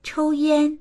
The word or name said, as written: chou1yan1